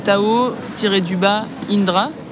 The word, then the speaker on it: Tao-Indra: Oui, il y a, bien que ce ne soit pas ce que j'aime le plus, il y a sur Instagram, mon nom c'est Tao-Indra, qui est mon deuxième prénom, tout comme ça se prononce.